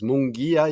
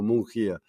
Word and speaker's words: Munguía: Es Munguía y yo a veces lo pronuncio como Mungía.